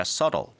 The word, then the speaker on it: subtle: So, subtle 하다는 건 발음 자체도 subtle이 아니라 subtle 되겠고요. 더 교묘한, 뭔가 더 눈에 띄지 않는 이라고 합니다.